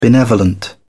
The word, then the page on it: benevolent: British English